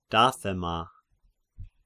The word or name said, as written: Dathema